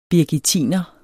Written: birgittiner

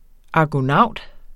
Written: argonaut

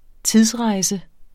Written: tidsrejse

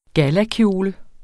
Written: gallakjole

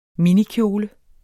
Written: minikjole

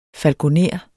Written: falkoner

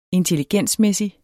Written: intelligensmæssig

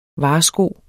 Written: varsko

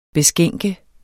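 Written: beskænke

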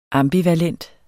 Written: ambivalent

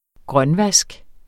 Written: grønvask

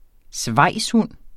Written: svejshund

